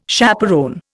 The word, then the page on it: chaperon: Indian